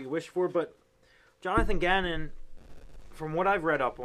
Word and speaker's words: Gannon: His name rhymes with cannon. That's something big on defense. You want a guy who's a cannon. You think about that, you know, hey, whoa, watch out. Here's Jonathan Gannon, the cannon.